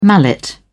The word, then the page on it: mallet: British English